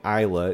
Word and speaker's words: Iella: And you pronounce her name Isla. It's spelled I-E-L-L-A.